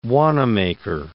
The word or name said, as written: Wanamaker